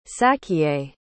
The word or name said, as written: sachiye